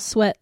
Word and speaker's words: SWT: Oh gosh, well, at me on Instagram, Sweat Fitness, and we spell sweat like SWT yes. – Fitness. But we pronounce it as sweat.